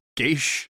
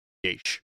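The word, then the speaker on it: Geas: The very, very commonly mispronounced Geish. That's G-E-A-S spell, everyone. If you're ever wondering how to say it, is an old Irish term or Irish word, Geish.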